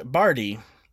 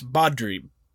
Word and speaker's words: Badri: Jim heads out about 2 miles away to an apple orchard owned by a man named Bardi. Oh, I'm sorry, not Bardi. It's okay. It's spelled B-A-D-R-I. It's Bodri. Bodri.